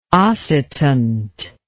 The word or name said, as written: oscitant